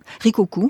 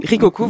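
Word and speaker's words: Rikkoku: comment prononcez-vous ? – Rikoku. – Rikoku, voilà.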